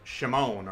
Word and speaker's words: Shimon: So Simeon is, you know, is more better pronounced in Hebrew, like Shimon, or right?